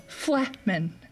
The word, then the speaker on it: Flehmen: Flehmen. F-L-E-H-M-E-N, Flehmen, to determine whether a smell is good or bad.